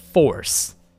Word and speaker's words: Force: 就是星际大战纪念日啊，这其实是一个很中二的一件事情哦，因为在电影里头他们都讲 May the Fourth be with you 嘛，对不对？Actually，其实应该是刚刚发音不太准，应该是 May the Force be with you，然后呢，谐音就有点像 May the Fourth，所以就是五月四号就变成星际大战日啦，啊。